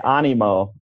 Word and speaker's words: Animo: well, I say Animo. Animo. – Animo. – Animo. Like animate, right?